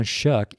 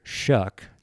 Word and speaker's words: Choque: And the show, again, is J-O-H-N, and my last name, C-H-O-Q-U-E, John Shuck. And it's John Shuck is Touching People for Heaven. Always use a C for Shuck, it's like the car Chevrolet. It's not Chevrolet, it's Chevrolet. It's spelled with a C and not with an S.